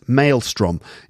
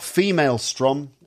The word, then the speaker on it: maelstrom: Maelstrom. Yes, not female strom, as Kate said. Ha ha ha, very funny. no, maelstrom is spelt M-A-E-L-S-T-R-O-M.